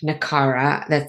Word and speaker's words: Nakara: On the planet of Nakara. That's what I'm going to go with for the pronunciation until I hear otherwise. But no, so that's like, how they pronounced it.